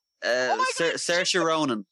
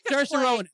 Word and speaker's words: Saoirse Ronan: Saoirse Ronan. – Saoirse Ronan. That's right. It's pronounced nothing like what it says.